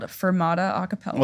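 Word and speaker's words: Furmata: To make the name of mine worse, it's Fermata, but it's spelled with a U, like F-U-R, because the mascot is a husky.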